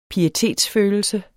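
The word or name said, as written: pietetsfølelse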